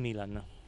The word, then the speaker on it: Milan: I've heard that he sometimes pronounces it Milan and not Milan. – I think in Italian, Milan. Okay. This is my choice.